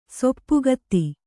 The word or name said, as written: ಸೊಪ್ಪುಗತ್ತಿ